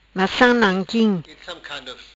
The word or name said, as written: Macam nanquim